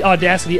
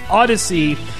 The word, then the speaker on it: Audacy: That is spelled A-U-D-A-C-Y. The first time I read it, I thought it was Audacity, the music editing program, but it's not. It is Odyssey, kind of audio and Odyssey combined.